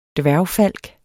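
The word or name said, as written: DVÆRGFALK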